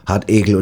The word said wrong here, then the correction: Hartriegel: Hartegel hieß es doch nicht, Hartegel. – Quatsch, Riegel. – Regel. – Blutrote Hartriegel. – Hartriegel, sowas.